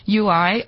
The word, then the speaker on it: YOUI: But so, it's so difficult to quantify whether or not UI are pronounced. It's spelt Y-O-U-I.